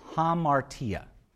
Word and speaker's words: hamartia: They used a word, it's hamartia is the word, hamartia. If you're going to spell it in English, H-A-M-A-R-T-I-A. hamartia.